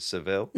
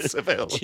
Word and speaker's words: Savile: And it was, he interviewed Stephen Merchant, but he kept talking about Jimmy Savile, but he just kept pronouncing it Seville. – Really, yeah. – He's not an old vaudeville fucking act. Jimmy Savile.